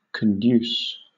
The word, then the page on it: conduce: British English